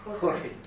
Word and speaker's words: Jorge: Hur säger man George på spanska? – Jorge.